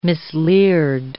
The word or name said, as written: misleared